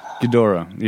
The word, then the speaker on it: Ghidorah: Ghidorah. – Ghidorah.